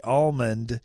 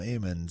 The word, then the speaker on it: almond: Just kind of like I say almond, but the guys out in California, I'll call them Amon.